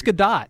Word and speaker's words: Gadot: So how do you say, again, I haven't figured this out yet, and I'm sure you people know, Gal Gadot, Gal Gadot. – That's it. – I believe it's Gadot. Yeah.